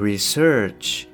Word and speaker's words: research: であとはですねあまあまあそんなところかなはいであとちょっと今僕あの気づいたんですけれどもえっ、と research ですね、えー、 research っていうふうにちょっと発音しちゃったんですが、えー、 research こちらの方が正しいです失礼しましたで、えー、 researcher の場合は、えー、頭に、えー、アクセントがきます